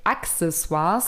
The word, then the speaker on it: Accessoires: Ähm, ja, und im Latein wird nämlich CC als K ausgesprochen und deswegen sagt man tatsächlich Accessoires.